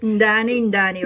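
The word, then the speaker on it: Ndani Ndani: Am I pronouncing it right? – Ndani, Ndani, right.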